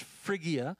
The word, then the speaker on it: Phrygia: Um, I got a response from an expert Greek um, lexical person this morning. It's pronounced Phrygia.